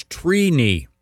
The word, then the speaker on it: Trini: The correct pronunciation is Trini. – Wow. – Trini. – Like T-R-E-E. – Like weenie. – Yeah, I is it phonetically T-R-E-E-K-N-I? – Yeah, it's, phonetically it is T-R-E-E.